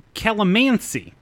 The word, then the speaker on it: calamansi: I'm being told it's calamansi.